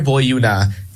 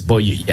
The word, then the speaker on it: Boiuna: So yes, Beware Boyuna, that's spelled B-O-I-U-I-A. Yes, I got it. – Wow. – No, so, yes, it's B-O-I-U-N-A. – Yeah. – B-O-I-U-N-A. There it is. Boyuna. Yes, you're right.